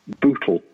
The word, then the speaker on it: Bootle: B O O T L E. – B oh, like Google only with the B.